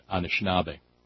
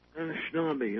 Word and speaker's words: Anishinaabe: Well, there are a number of bands of, there's different pronunciations based on how, white people misapprehended their names, Ojibwe or Chippewa, but it falls under the larger generalized, and this is the native word, Anishinaabe. – Anishinaabe, okay.